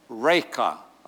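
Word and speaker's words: Raca: how do you pronounce this, Eugene? Reka. Okay, Reka. Am I doing okay, Betty? Reka. That'll do. Okay. Want to get it right.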